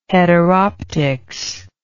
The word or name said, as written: heteroptics